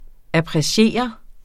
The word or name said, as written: appreciere